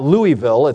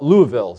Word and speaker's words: Louisville: By the way, I think I pronounced that right, right? It's not Louisville. It's not Louisville. The locals call it Louisville, so you got to kind of go with the flow.